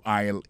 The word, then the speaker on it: aisle: Aisle là, c'est A-I-S-L-E. Vous prononcez l'aisle.